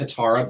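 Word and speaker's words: hetaera: And then I came across, I was researching, you know, the hetera. – Mm-hmm, I think it's pronounced hetara, but hetera.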